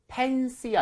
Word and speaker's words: fancy: 자, 아무튼 오늘은 fancy 아니라 fancy 화려한이라는 형용사 알아봤고요. 자, 그러면 발음 한번 알아볼게요. F니까 입술이 붙으면 안 되겠죠. 그래서 pen이 아니라 fancy.